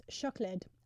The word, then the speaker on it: siocled: What I love about Welsh is, like, chocolate is pronounced chocolid, and it's written... SIOCLED, which is nice.